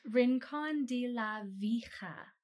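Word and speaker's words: Rincón de la Vieja: Okay. Rincon de la Vija. Vijin. Vijije. – It's Rincon de la Vija. – Rincon de la Vija. – Vija, I think.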